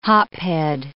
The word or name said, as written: hophead